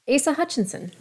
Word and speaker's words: Asa Hutchinson: Let me see. Asa Hutchinson. – Is you went you went with Asa? Asa, yeah.